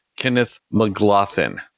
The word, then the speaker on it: Kenneth Mcglothin: We have from the movie Monday, Kenneth Mcglothin.